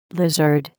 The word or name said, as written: lizard